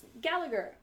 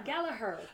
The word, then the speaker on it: Gallagher: Oh, Gallagher. There we go, that's how you say it. Gallagher.